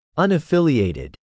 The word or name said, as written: unaffiliated